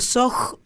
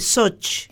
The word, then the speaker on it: Soch: Eh, ella es Anabela Sock eh, yo digo Soj, pero ella dice Soch. Exacto. Porque se escribe CH, eh, de su disco Raíces Arrancadas.